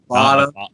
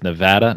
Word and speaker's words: Nevada: Nevada. – It is Nevada, not Nevada, folks. Nevada.